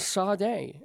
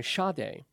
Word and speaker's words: Sade: And Sade, I'm sorry, Sade is how she pronounces it, with John Legend.